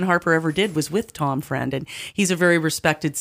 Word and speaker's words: Freund: Tom Friend is his friend, but it's spelled F-R-E-U-N-D.